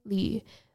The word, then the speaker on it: Li: As for my last name, Li. Li is the pinyin pronunciation of the Chinese surname.